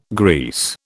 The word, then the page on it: grease: British English